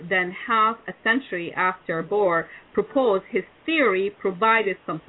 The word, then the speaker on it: Bohr: So based on Bohr, I don't know if you know who he is, B-O-H-R, if I say his name right. – Sure, Niels Bohr.